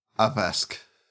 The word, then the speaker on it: Abheski: While we're on the places tab here, we have the word A-B-H-E-S-K. Can you pronounce that, please? – Abheski.